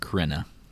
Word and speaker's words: Crenna: I think it's pronounced Crenna. – Is it Crenna? – Yeah, okay, I didn't know. – You added an E in there, but it's fine.